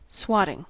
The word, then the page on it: swatting: American English